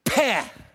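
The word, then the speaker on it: peh (פ): It's the 17th letter of the Hebrew alphabet, and it is pronounced in Hebrew, peh. We spell it in English, P-E-H.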